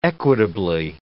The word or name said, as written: equitably